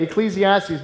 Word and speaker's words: Ecclesiastes: Okay, Ecclesiastes.